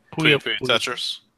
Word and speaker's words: Puyo Puyo Tetris: Puyo Puyo Tetris.